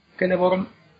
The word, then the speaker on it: Celeborn: Bueno, pues la, la C siempre se pronuncia como K. O sea, siempre es Kirdan, Kelleborn, etc.